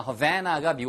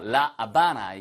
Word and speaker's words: La Habana: 예, 하바나라고 하잖아요. Havana가 미국식 발음이고요. La Habana. 이게 바로 스페니쉬식 발음인데 정말 예쁜 도시입니다.